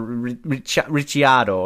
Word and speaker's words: Ricciardo: It's also like Ricardo, because I think in an interview I heard from Daniel Ricardo, he said it should be pronounced Riccardio or Ricciardo or something like the Italian.